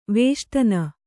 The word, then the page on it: ವೇಷ್ಟನ: vēṣṭana